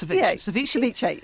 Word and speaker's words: ceviche: Ceviche, I'm never sure of the pronunciation. It has the E on the end, I think. – Yes, ceviche. Yeah.